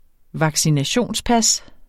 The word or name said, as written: vaccinationspas